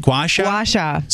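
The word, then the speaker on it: gua sha: Guasha.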